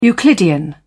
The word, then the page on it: euclidean: British English